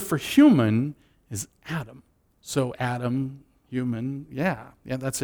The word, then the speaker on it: Adam: Now we have to stop right there, because the word man is the Hebrew word Adam, or Adam, as it would be pronounced there.